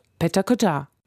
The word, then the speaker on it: Pecha Kucha: Übrigens, eigentlich spricht man Pecha Kucha so aus. – Pecha Kucha. – Ich bleibe aber lieber mal bei der eingedeutschten Version.